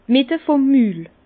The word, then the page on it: d' Mitte vum Mül: Bas-Rhin; Herrlisheim